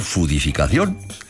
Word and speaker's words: foodificación: Puedo escribir foodificación y pronunciar fudificación. La primera parte del palabro, como hubiésemos dicho en mi pueblo hace años, neologismo, como diríamos hoy más cultamente, es inglés, food, food, alimento.